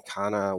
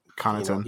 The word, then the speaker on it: Connaughton: Kana, whatever. – Connaughton.